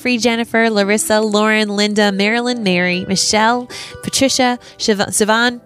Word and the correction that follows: Siobhan: Am I saying that right? – Siobhan. You still can't get, this is like 2 years in a row, you still can't get it right.